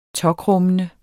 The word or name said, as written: tåkrummende